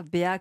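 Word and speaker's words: Béa: Ça s'appelle l'agence BA, BA comme une BA, mais ça s'écrit B-E-A avec un accent évidemment.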